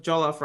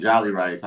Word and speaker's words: jollof: Oh, that's how you say it. Jollof. How do you Jollof? – jollof? – How do you say it then? – Jollof. – Low key, I just kind of said jolly rice.